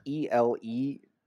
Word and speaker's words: Elegoo: It is spelled ELEGOO.